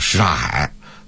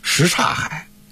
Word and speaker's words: 什刹海: 你比如说这个什刹海，刚才咱们说了，本身他这个发音就有问题。应该叫什刹海啊，但是很多北京人就是什刹海，你要说快了，变成上海了，上海，哎，那个大家也是，但是现在很多的播音员，你听过，大家看看了啊，大家看看这个事儿是怎么回事啊？